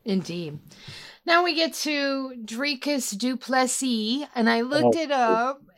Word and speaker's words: Dricus du Plessis: His last name is with the I and without with the silent S. But you're right. Okay. His first name is with the hard S. So Dricus du Plessis, man, I hate saying this out loud, defeated Robert Whittaker, and he made it look easy.